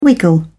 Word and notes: wiggle: British English

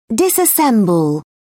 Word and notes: disassemble: British English

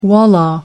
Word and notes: wallah: American English

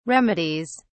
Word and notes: remedies: British English

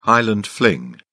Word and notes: highland fling: British English

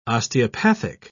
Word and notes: osteopathic: British English

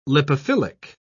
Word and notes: lipophilic: British English

